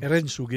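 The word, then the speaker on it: Herensuge: Dans ce disque, il y a également l'Erensu, je ne sais pas comment on le prononce. – Erensuge. – Erensuge, ouais.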